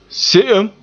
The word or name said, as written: se'em